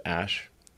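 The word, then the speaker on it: Æ: I mean, it's just X, the letter X, and then the AE is like pronounced Ash.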